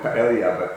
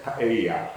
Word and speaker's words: paella: Now we had this roasted cochineal, and we had the other specialty of that restaurant was, I called it paella, but the waiter corrected my pronunciation, paella.